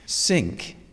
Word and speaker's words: Cinque: Just on pronunciation, we need to make this clear, don't we, that it's Cinque. – Yes. – In English, but so Cinque in French. – Yes, absolutely. So it reads as Cinque Ports, and people tend to want to say Cinque Ports because we know that's what the French for five would be.